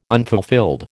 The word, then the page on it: unfulfilled: American English